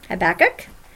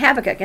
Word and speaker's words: Habakkuk: You're going to find when you study this, there are two different pronunciations. Habakkuk and Habakkuk.